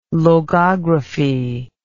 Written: logography

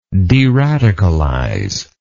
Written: deradicalize

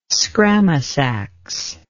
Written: scramasax

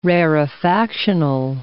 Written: rarefactional

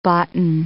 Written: boughten

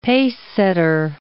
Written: pacesetter

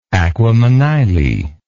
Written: aquamanile

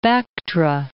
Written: Bactra

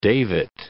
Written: davit

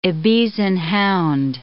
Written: Ibizan hound

